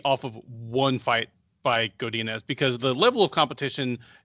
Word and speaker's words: Godinez: How do I say her name? I apologize. Yeah, Godinez, you got it.